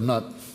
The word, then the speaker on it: Nut: And the goddess this affects is a sky goddess that's often referred to as Nut, but it's spelled N-U-T, and so I just prefer Nut.